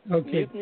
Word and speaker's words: Nielsen: Welcome to our person, Miss Nielsen, you said? – Nielsen.